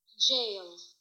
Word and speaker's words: gaol: No, it's pronounced jail. All right. – Jail. – That's what I thought. But, you know, it's so peculiar looking here in English that you think, oh, that can't be how it's pronounced. But in fact, it is.